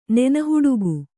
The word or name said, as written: ನೆನಹುಡುಗು